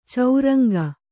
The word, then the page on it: ಚೌರಂಗ: Kannada